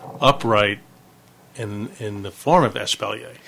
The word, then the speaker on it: espalier: When you grow vertically, like in the small yards in New Orleans, espalier? – Espalier. – I said it right. – Yes, espalier.